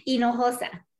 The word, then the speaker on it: Hinojosa: Hinojosa.